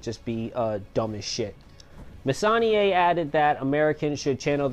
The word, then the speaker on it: Messonnier: I'm going to pronounce that Messonnier.